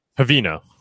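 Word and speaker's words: Haveno: And someone a couple years ago, I think 2 years ago, saw the issue and basically have Vino, I don't want to pronounce it wrong. You know how to pronounce it? – Havino. – Yep.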